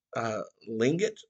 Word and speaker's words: Tlingit: And this tribe called the Tlingit Tribe, I think I'm pronouncing that wrong. It's spelled T-L-I-N-G-I-T, Tlingit, Tlingit, however.